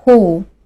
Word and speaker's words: who: 第三个单词，who，w h o who，这个词的意思是谁。比如说，问他是谁，Who's he？看一下这个词，它的第一个字母是不发音的。英语中这种现象比较常见，也就是说，这个字母确实存在于这个单词中，但是它并不发音。